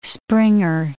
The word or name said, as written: springer